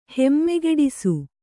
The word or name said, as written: ಹೆಮ್ಮೆಗೆಡಿಸು